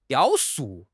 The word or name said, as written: 雕塑